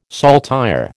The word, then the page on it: saltire: American English